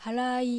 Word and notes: Harai: Japanese

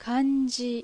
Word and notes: Kanji: Japanese

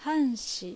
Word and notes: Hanshi: Japanese

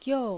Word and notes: Gyou: Japanese

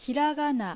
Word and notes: Hiragana: Japanese

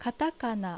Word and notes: Katakana: Japanese

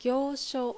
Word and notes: Gyousho: Japanese

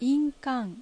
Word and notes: Inkan: Japanese

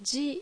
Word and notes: Ji: Japanese

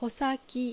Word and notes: Hosaki: Japanese

